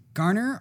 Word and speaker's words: Garner: Garner or Gardner? – You're going to have to make sure I don't want to butcher her name. – That was in 2005. – Yep. – Elektra Gardner, G-A-R-N-E-R.